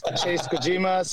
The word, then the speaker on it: Chase Kojima: So my Instagram is Chase Kojima, CHASEKOJIMA.